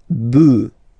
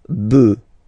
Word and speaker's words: bœufs: so you just add this S, but then pronunciation changes quite much, because you get des bœufs. Des bœufs. All right, un bœuf, des bœufs.